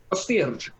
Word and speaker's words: Gostilj: So with research, you know that these GPS coordinates lead to an Orthodox cemetery east of a name that I'm going to butcher horribly, Gostilj, which is spelled Gostilj. Good luck. – Gostilj. – Yeah, that sounds like a pretty good pronunciation.